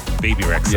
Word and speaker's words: Bebe: It's BB, isn't it? – BB Rexha. – Yeah, BB. – BB. – I used to say Bebe. – Bebe. – Bebe Rexha.